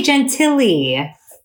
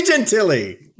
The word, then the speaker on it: Gentile: See, Gentile. – I didn't say gentilly. – You said gentilly. – I did say gentilly. Gentile? – No, just move on. That's why we don't pronounce it like that.